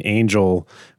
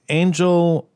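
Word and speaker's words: Ángel: So Angel is Hispanic, and he actually corrects Lucy's pronunciation. She tries to call him Angel, and he says, no, it's Angel.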